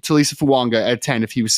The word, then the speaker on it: Fuaga: we've been calling him a Talisi Fuanga, but apparently it's pronounced Talisi Fuanga. Apparently there's an N in there as well.